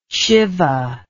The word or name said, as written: shivah